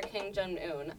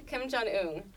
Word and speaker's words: Kim Jong-un: Vladimir Putin or Kim Jong-un? I think I mispronounced that. Kim Jong-un.